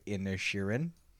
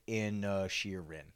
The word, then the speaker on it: Inisherin: I just Googled how to say Banshees of Inner Sheeran. – Okay. – And it literally, Banshees of Inisherin. – Good. Okay. – So we were saying it right.